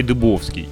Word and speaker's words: Дыбовский: Я сейчас даже проверил на Ютубе, он Дыбовский себя произносит. – А я как сказал, Дубовский, да? Ну, может быть. – Не, нет, это я, я никак не, не знал, как правильно ударение ставить, он дыбовский.